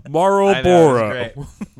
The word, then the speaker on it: Marlboro: Wow, that was the first time in your life you've ever said Marlboro. And it's incredibly clear because you pronounced every consonant. Marlboro.